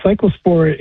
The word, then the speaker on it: cyclospora: Is it cyclospora? Am I saying that correctly? – Yeah, cyclospora is a parasite that is the agent that causes the disease.